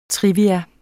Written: trivia

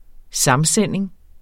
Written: samsending

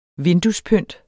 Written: vinduespynt

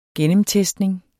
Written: gennemtestning

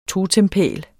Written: totempæl

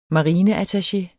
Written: marineattaché

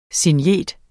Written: SIGNETER

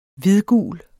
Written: hvidgul

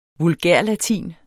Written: vulgærlatin

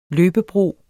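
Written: løbebro

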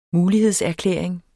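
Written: mulighedserklæring

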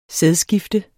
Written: sædskifte

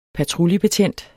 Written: patruljebetjent

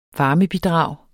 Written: varmebidrag

